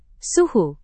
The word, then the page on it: suhu: Indonesian